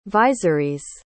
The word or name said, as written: viserys